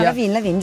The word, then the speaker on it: Levine: E il cantante di Maroon 5, Adam Levine, o Levine che dir si voglia. – No, Levine, giusto, Adam Levine.